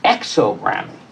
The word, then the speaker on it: exogramy: Exogamy precedes oxogramy. Excuse me, because now I mispronounced my own made-up word. Exogamy precedes exogramy. Exogramy.